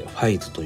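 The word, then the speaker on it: 555: 5 を 3 つ書いてファイズと読みます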